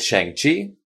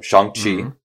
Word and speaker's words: Shang-Chi: The way they have written is pronounced chur, which is how you say to eat. So Shang can mean like on, like Shanghai means on the sea. So Shang-Chi, or Shang-Chi as it's spelled, is, I think, means on the eating.